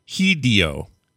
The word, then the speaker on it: Hideo: So Hideo is spelled Hideo. Hideo. Like Hideo. Not Hideo. Hideo. This emphasis is traditionally on the D sound in Japanese. E is always the short E sound. In Japanese, the O always is the long O sound. There simply is no short O sound in Japanese.